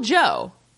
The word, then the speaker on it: Gio: Uncle Gio. No, it's Uncle Joe. They call him Uncle Joe, but they call the restaurant Gio's. – Well, I think they're just pronouncing it Joe, because I looked in IMDb, and G-I-O is his name.